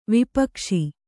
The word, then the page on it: ವಿಪಕ್ಷಿ: vipakṣi